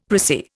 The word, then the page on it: pricy: Indian